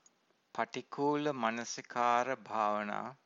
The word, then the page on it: patikula-manasikara-bhavana: Pali